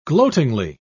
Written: gloatingly

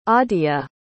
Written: Aadya

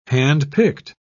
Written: handpicked

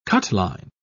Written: cutline

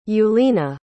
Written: julina

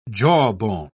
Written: jawbone

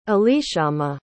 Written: elishama

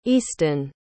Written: easton

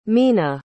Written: mhina

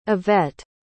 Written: evette

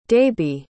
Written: daibhidh

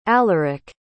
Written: alaric

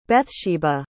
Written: Bethsheba

